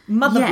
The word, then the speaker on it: Mutha: You now write your own mother blog. – Yeah. – What's that called? – That's called Mother Courage, but it's spelled M-U-T-H-A. – Mother. – Like bad mother.